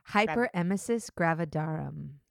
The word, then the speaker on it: hyperemesis gravidarum: Hyperemesis, gravidarum.